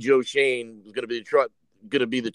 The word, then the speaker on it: Schoen: Schoen is the way to pronounce it.